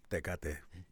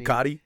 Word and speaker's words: Tecate: Tecate. – That's how you say it. No matter if you're white, Mexican, black, Chinese. – Tecate.